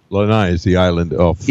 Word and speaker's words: Lanai: And Lanai, I believe is the proper pronunciation, is where 75% of the plantation staff lived.